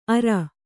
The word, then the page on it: ಅರ: kannada